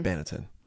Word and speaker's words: banneton: for sourdough proofing than the standard kind of wicker baton mm-hmm. banneton. Banneton. Uh, you always butcher that one.